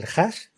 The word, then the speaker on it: hash: Que para ello hace una operación matemática que devuelve un código, un número alfanumérico, que es el hash, ¿no? El HASH, perfecto, lo he dicho bien, hash.